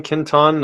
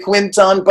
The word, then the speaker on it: Quinton: Quinton? You say it so well. – It kind of doesn't matter. Yeah. English people say Quinton, but the real thing is the French pronunciation is Quinton.